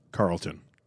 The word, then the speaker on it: Charlton: Carlton? – The H is silent.